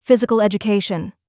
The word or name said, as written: physical education